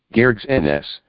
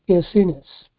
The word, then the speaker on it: Gerasenes: Gerasenes. – Gerasenes.